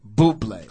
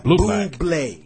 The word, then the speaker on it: Bublé: Bublé. – Close. A lot of people call him Bublé. – How do I say it? – Bublé.